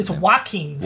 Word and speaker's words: Joaquin: It's Joaquin.